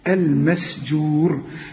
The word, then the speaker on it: المسجور: والبحر المسجور، والبحر الراء مرققة، ساعدتك على نطق كلمة البحر المسجور. شوف السين انت قلتها صحيح علشان برضو الجيم هنا مرقق، المسجور. ورقق السين.